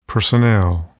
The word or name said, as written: personnel